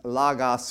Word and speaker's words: logos: It uses logos, and that's pronounced logos for a reason.